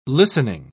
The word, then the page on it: listening: British English